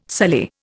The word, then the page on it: sully: Indian